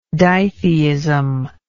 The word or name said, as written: ditheistical